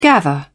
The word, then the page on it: gather: British English